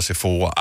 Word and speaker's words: Sephora: Og det var først der, min hjerne har aldrig nogensinde brugt tid på at skulle spekulere over, hvordan udtales S-E-P-H-O-R-A. Sephora, Sephora, I don't know. Men det var selvfølgelig Sephora.